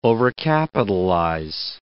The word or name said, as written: overcapitalize